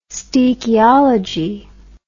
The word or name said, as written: stoechiology